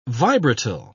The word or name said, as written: vibratile